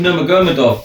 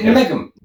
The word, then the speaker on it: Nurmagomedov: No, say his name properly. – That is his name. – No, it's not his name. – Is it Numegum? – Nurmagomedov. – It's not. Numega? – I'll look it up.